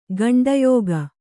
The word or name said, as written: ಗಂಡಯೋಗ